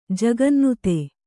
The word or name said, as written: ಜಗನ್ನುತ